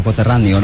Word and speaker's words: coterráneo: Por acá está Ricardo, un coterriano. ¿Cómo se llama? ¿Cómo se dice esto? Coterráneo, ¿no? – Coterriano.